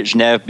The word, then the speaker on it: Geneve: So I pronounce it Geneve, but I think I'm the only person that does, at least for English speakers. So the history was we named it Geneve because it was supposed to be kind of a coming together and a meeting place in Geneva, Switzerland. And Geneve is the original French pronunciation, but pretty much all the English speakers call it Geneve.